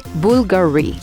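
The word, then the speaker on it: Bvlgari: Bulgari, it's written B-V-L-G-A-R-Y. So people might think, you know what? Let's pronounce the V along with the B. But in this brand name, you do not pronounce the V. You just say Bulgari. Jacquemus. Jacquemus, it's written J-A-C-Q-U-E-M-U-S.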